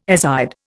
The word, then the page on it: azide: Indian